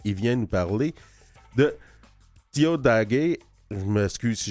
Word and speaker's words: Tiohtià:ké: oui, Diodagé, avec, euh, en fait, comme je disais à quelqu'un hier, c'est, c'est des T et des, euh, K, mais il faut prononcer des D et des G.